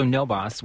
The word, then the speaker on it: NoBAWC: So No Boss, which is actually NOBAWC, stands for the Network of Bay Area Worker Cooperatives. We just really enjoy to pronounce it as No Boss.